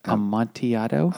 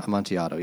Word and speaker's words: Amontillado: Amontillado. – Amontillado.